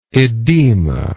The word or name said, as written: edematose